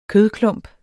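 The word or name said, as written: kødklumper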